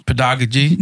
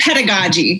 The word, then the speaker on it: pedagogy: I saw a word here, and I could not pronounce it. You have to help me here. It's P-E-D-A. – Pedagogy. – I was afraid to say it because I knew I was going to sound like a dummy. – Like, no, that's such like a typical like teacher buzzword.